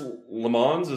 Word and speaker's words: LeMons: Yep, and Chris LeMons. Is that the way you pronounce it? – Sure. – Yeah. They keep saying LeMons on the broadcast.